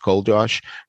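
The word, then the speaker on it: Czolgosz: I mean, would you think that C-Z-O-L-G-O-G SZ is pronounced Cold Josh?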